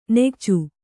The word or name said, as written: ನಿಕೋಚ